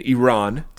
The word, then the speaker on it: Iran: Iran.